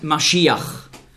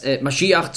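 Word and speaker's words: Maschiach (משיח): In meiner Bibel steht Messias, weil im Grundtext hier das Wort Maschiach, so ist die hebräische Aussprache, zu finden ist. Messias ist eine sprachliche Anpassung ans Griechische. Nicht wahr? Im Griechischen konnte man den Sch-Laut nicht aussprechen.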